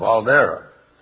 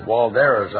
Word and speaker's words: Waldera: But Waldera is spelled W-A-L-D-E-R-A, but the German doesn't allow you to pronounce the W as wa. It's va. So the proper pronunciation of the horse that was a German warm blood, and thus, or Dutch warm blood, and thus named in the German context, was properly pronounced Waldera.